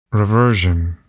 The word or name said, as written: reversion